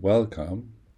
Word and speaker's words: welcome: Then the last word, welcome, has two syllables, well and come. The stress is on the first one, not the second one.